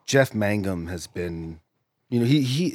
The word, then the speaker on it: Mangum: How do you pronounce his name? – Jeff Mangum? – It is. That's correct. – Yeah. – It's not Magnum. – It's not Magnum. – I used to think it was Magnum.